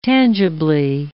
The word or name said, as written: tangibly